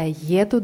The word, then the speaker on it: еду: Я еду домой. Die Betonung ist auf dem ersten Je.